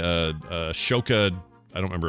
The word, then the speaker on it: Ahsoka: The H comes before the S, so it's Ahsoka.